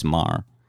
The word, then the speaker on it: Meaghre: No, he spelled it M-E-A-G-H-R-E. Thomas Marr is how he pronounced it.